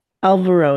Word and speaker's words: Alvaro: And if you wanted to, like, I can never say his name right. Alvaro. – Alvaro, yeah.